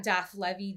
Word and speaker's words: Daph Levy: You can find me on social media um, at Daph Levy, D A P H L E V Y.